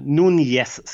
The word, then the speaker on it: Nunes: Nunes ska jag säga för man ska ju uttala det rätt här enligt Bengt Grafström på det spanska sättet.